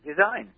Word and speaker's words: Desyne: It's spelled D-E-S-Y-N-E. Let's say it's called the Design. – Design? – Design. Design. Oh, Design. Design spelled wrong.